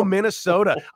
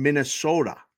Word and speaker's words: Minnesota: Did you pick up the long O in Minnesota? I mean, everybody that visits Vegas from Minnesota says it like with a long O, and I like to hear it.